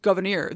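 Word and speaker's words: Gouverneur: Again, his name is derived from his mother's Huguenot French Protestant name, surname, pronounced Governor.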